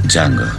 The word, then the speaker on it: Django: Django. – Sai come si scrive? – D-J-A-N-G-O. La D è muta.